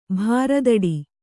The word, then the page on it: ಭಾರದಡಿ: bhāradaḍi